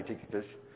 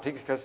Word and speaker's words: Tychicus: Let me list Tychicus. Is that how you say it? – I say Tychicus. Well, tomato. Tychicus is how I say it.